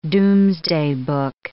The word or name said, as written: Domesday Book